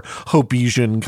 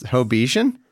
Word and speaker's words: Hobbesian: It's a, yeah, is it pronounced Hobbesian? – I have often heard it that way. – Oh, wow. – You can say Hobbesian as well.